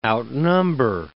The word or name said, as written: outnumber